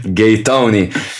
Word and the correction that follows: Gaetano: My God, I can't say his name. – You just called him Gaetoni. – Fuck you, you did this to me. In the 88th minute, Gaetano